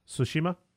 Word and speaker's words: Tsushima: Tsushima? – Yeah. – Tsushima, to July.